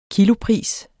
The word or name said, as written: kilopris